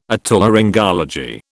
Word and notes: Otolaryngology: British English

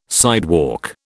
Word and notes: sidewalk: British English

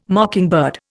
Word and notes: mockingbird: Indian

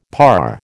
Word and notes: parr: American English

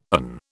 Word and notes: an: British English

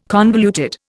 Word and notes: convoluted: Indian

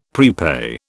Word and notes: prepay: British English

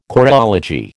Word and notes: choreology: American English

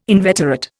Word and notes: inveterate: Indian

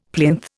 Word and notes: plinth: Indian

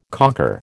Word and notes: cocker: American English

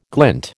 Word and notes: glint: American English

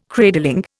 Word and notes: cradling: Indian

